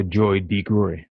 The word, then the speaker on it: Joy DeGruy: It's Dr. Joy DeGruy.